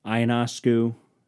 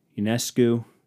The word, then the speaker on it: Ionescu: I think Sabrina, I'm going to butcher her last name, Ionescu, Ionescu, Ionescu, I think is how it's pronounced.